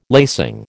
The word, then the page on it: lacing: American English